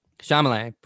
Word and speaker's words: Chalamet: This 2018 film starring Steve Carell and Timothy Chalamet portrays the relationship of a father.